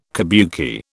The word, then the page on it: kabuki: British English